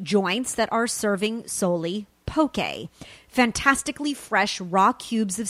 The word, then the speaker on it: poke: It's actually a culinary conundrum that few on the continental U.S. can actually pronounce. It's poke, by the way, and it's the next big thing in fast food.